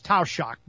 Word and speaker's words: Taoiseach: And this expanded acquisition program, according to Michael Martin, the Irish prime minister, we call him Taoiseach. That's the Irish Gaelic name is Taoiseach. Doesn't sound anything like it's spelled, but that's how it's pronounced.